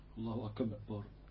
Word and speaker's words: الله أكبر: قالوا أيضا ولا يشدد باء أكبر، فيقف عند الباء ويشدد. أنا مش عارف أنطقها كويس. – الله أكبر.